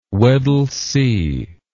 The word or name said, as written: weddell sea